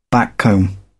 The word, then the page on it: backcomb: British English